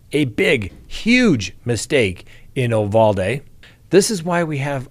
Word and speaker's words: Uvalde: All right, aficionados, Uvalde. That's the Spanish pronunciation. That's right. I've got this from reliable sources. It's pronounced Uvalde.